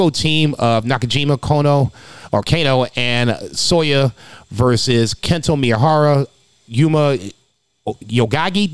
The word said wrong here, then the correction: Aoyagi: did I say it right? – I think it's Aoyagi.